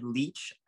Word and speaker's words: Leitch: It's a movie directed by David Leitch. I hope I didn't butcher that. – Oh, yeah. You pronounced it right.